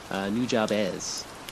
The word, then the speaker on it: Nujabes: It's pronounced Nujabes.